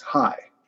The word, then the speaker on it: chai: Apparently chai, C-H-A-I, is a Hebrew word and symbol that means life. And pronounced in English, it's high.